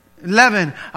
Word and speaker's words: leaven: Leaven. Oh, it's your accent. Leaven. Sorry. It's your accent. Yes, leaven. So it rhymes with heaven.